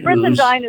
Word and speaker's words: Brizendine: The Upgrade is by Luann, and I'm going to mispronounce her name, Brizendine. I hope I've got it somewhat close, Luann, who's a physician. Brizendine is good. That's okay. Brizendine.